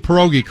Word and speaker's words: pierogi: You are pronouncing pierogi correctly. Now, there are a lot of different spellings, and there's a lot of different pronunciations, and some people roll their tongue, pierogi, but pierogi is fine.